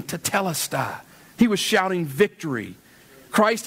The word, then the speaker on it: tetelestai: Tetelestai.